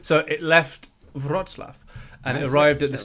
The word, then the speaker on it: Wrocław: So it's spelled WROC, L with a line through it, AW, and it's pronounced, let me listen again, Wrocław.